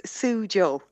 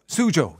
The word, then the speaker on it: Suzhou: Wexford teacher has been living in China since last August, and she joins me now from Suzhou. Is that how you pronounce it? – It's Suzhou. – Suzhou, yes. Okay.